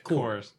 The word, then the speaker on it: Corps: Corps.